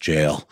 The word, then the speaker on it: gaol: And then Ben very kindly patted me on the head virtually and said, I think it's pronounced jail.